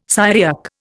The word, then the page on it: Syriac: Indian